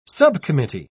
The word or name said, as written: subcommittee